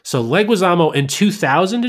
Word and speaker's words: Leguizamo: Leguizamo? – Yeah, Leguizamo. – I really can't pronounce anybody's names, can I? – No, you're usually pretty good. His is weird. Like, you said it right phonetically.